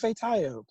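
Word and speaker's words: Ifetayo: excuse me, it's Ife Tayo, please say it correctly, you know, things like that.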